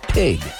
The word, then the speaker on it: pygg: The color of the clay was spelled P-Y-G-G and pronounced pig.